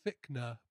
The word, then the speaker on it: Fichtner: It's pronounced Fichtner, but it's like C-H.